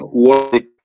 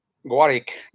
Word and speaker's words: Warwick: Él es de la Universidad de Warwick, ¿es correcto la pronunciación? – Bueno, le dicen Warwick, por alguna razón, pero sí. – Warwick, gracias. Y bueno,